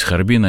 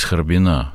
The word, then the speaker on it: Харбина: Все вот, так сказать, ведь, как говорят, не из Харбина, а из Харбина.